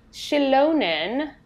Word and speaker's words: Xilonen: The deity Shilonen, I'm assuming that's how it's pronounced. It's Xilonen. – Mm-hmm. Yes, that's correct.